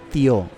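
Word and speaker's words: tió: Eh, es tío, es como el acento en, la O.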